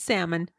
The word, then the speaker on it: salmon: No, it's salmon. Drop the L. The L is silent.